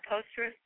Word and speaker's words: Postgres: Is that how we pronounce that, Postgres? – Yeah.